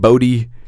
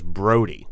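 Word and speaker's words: Bodhi: Okay, he's on the trail of what's his name, Bodie. – Yeah. – Bodie Bruce. – Mm-hmm. Not spelled the way you would expect it to be spelled. A little Indian. There's an H in there, the silent H.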